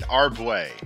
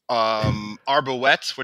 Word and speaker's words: Arbue: That is not bad. Arbue. – Arbue. – But that's not bad. I get Arbouet, which is like the English version, which I answer to, but then I also get Arbonet, Arboquette in the mail.